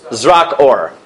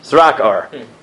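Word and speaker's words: Zrak or (זרקור): Zrak or. Zrak or.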